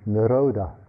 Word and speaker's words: nirodha: So, in the Pali language, the word for cessation is nirodha, which is spelt, I think, N-I-R-O-D-H-A.